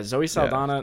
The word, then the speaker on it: Zoe Saldana: Zoe Saldana, right? – Yeah. – Yeah, Zoe Saldana, yeah.